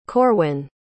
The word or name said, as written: corwin